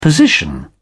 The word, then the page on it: position: British English